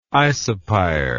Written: isopyre

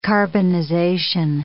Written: carbonization

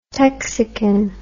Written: texican

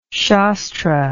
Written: shastra

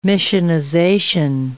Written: missionization

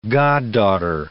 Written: goddaughter

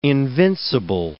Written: invincible

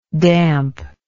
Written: dampness

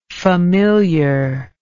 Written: familiarness

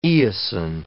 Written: eosin

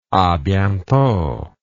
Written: a bientot

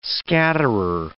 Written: scatterer

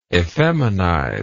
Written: effeminize

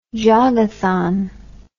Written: jogathon

